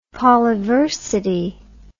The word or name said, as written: polyversity